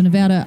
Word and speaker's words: Nevada: I've always been told you're supposed to say Nevada. You're not supposed to pronounce it the proper Spanish way. – You're supposed to pronounce the A. – Okay, so Nevada, I'm happy that Nevada is going slower in the reopening than some other states.